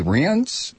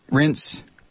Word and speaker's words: Reince: R-E-I-N-C-E. Rince Priebus.